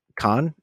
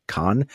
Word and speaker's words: Kon: Also, thank you for not pronouncing it Khan. I feel like everybody in the world who ever gets on mic pronounces it Satoshi Kon. And I feel like you, how do you do that with that diacritic, with the flat diacritic over the O in cone?